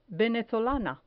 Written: venezolana

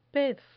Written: pez

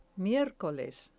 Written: miercoles

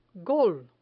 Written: gol

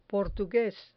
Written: portugues

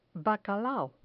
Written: bacalao

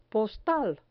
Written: postal

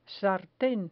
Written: sarten

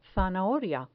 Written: zanahoria

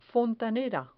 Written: fontanera

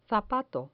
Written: zapato

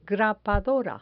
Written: grapadora

